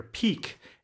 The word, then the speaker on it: pique: This is a good word here, peek, and it's spelled PIQUE, meaning you're sort of stimulating your curiosity or your interest, and it's used as an expression like this, to pique your interest.